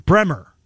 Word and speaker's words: Bremer: Barry Bremen. Bremer is how you say it.